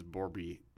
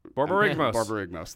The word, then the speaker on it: Borborygmos: I have it in, I have a my red green deck is Borborygmos. – Borborygmos, thank you.